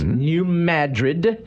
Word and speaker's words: New Madrid: The new Madrid, not Madrid? – No, it's not pronounced Madrid. It's New Madrid, and it's the fault line.